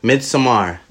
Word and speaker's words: Midsommar: Midsommar.